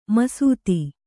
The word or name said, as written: ಮಸೆತ